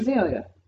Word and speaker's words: Azalea: And Iggy Az, Az, Azalea, how do you say her name? Iggy Azalea.